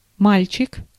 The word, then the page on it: guy: /ˈɡaɪ/; [ɡaɪ]